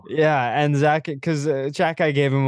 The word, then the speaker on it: Xhekaj: His nickname is Wi-Fi because his last name is spelled like Xhekaj and it's pronounced Jack Eye.